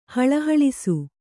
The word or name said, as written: ಹಳಹಳಿಸು